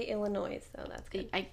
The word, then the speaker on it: Illinois: So, and I also think we're pronouncing it Illinois. It's just Illinois. – Illinois. – Whatever. Moving on. – Silent.